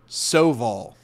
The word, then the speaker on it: Soval: Do you call him Suval because it's part of your bit of not pronouncing things correctly? Or I don't know what's his name. – Soval.